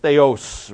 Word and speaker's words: theios: I know it's true, but This is theos, T-H-E-I-O-S, theos, really it's pronounced, and it means divine or of the one true God.